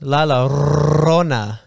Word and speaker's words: La Llorona: Llorona. – Yeah. That one or the chupacabra. – It's outside. Pronounced La Llorona.